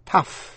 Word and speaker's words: tough: Một số chữ tận cùng bằng O-U-G-H. Trong đó có 3 chữ là tough, cough và enough. Phát âm tận cùng bằng F. Thí dụ, chữ tough, T-O-U-G-H.